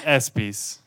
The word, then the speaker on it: ESPYs: ¿Estoy pronunciando esto bien? – Bueno, sí, los ESPYs.